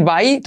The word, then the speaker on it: by: Puedes decir by, todo el mundo debe entender, pero verás que los nativos siempre dicen by. By, lo mismo que adiós, by, se pronuncia igual. By, by. Y como E, un diptongo donde la I al final es tan corta que parece una E. Se acerca casi a una E.